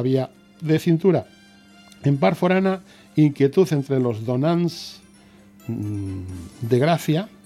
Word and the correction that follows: Donats: No lo he pronunciado bien, sobre una N. Donats de Gracia por su futuro en el santuario.